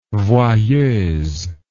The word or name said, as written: voyeuse